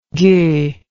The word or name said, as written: gueux